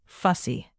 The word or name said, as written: fussy